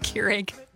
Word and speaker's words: Keurig: I'm not a fan of the Krieg. – Keurig? – Yeah. Yeah. – It's pronounced Keurig.